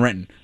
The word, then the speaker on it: Renton: Two, you never pronounce the T in Renton. It's Renton.